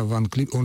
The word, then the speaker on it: Ван Клиберн: Короче говоря, на вопрос Романа, я так понимаю, следует ответить, мы относимся к этому отрицательно, это неправомерно, тем более, что существует пока еще довольно устойчивая традиция произношения имени именно этого пианиста. Когда Ван Клиберн, он же ведь пользовался в нашей стране невиданной популярностью после того, как он победил на конкурсе имени Чайковского и победил столь убедительно.